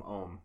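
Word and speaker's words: Oum: Not Ohm, Ohm is how you pronounce his name.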